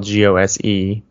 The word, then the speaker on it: Gose: It's spelled G O S E, but pronounced Gose.